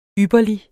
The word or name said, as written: ypperlig